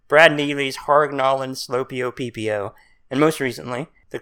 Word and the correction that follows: Sclopio: You said Slopio, I think. It's Sclopio.